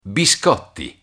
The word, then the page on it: biscotti: Italian